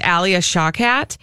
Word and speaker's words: Alia Shawkat: That's the voice of, I think her name is pronounced Alia Shawkat.